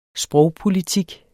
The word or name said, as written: sprogpolitik